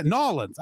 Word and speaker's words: New Orleans: Yeah, Nolens. I got to say it correctly.